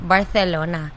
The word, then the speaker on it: Barcelona: Barcelona. – What's that from? – The Spaniards pronounce their C's like fifth like THs, so Barcelona.